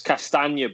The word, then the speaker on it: Castagne: But it's Castagna boys. That's how you pronounce it. We're a multilingual podcast here. Come on, boys, let's get it right. – What did I say? – Castagne.